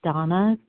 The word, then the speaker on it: Dana: My name is Donna B. It's spelled D-A-N-A.